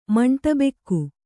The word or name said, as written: ಮಾಂಟಬೆಕ್ಕು